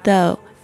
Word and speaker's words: though: 我们来看一下，一开始呢，他用这个 though 特别注意一下他的发音 though 这个声音是发这个两排牙齿咬住舌尖的这个有声的声音的声音 though。那另外一个字呢是 although A-L-T-H-O-U-G-H